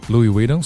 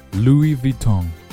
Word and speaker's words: Louis Vuitton: Louis Vuitton 俗称驴牌 Louis Vuitton Louis Vuitton 注意那个 S 千万不要发音